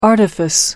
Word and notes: artifice: American English

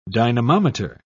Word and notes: dynamometer: British English

